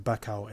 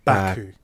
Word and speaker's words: Baku: Baku looks, have I said it right this time? – I was going to correct you earlier on because you said Bacau again.